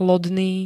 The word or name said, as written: lodný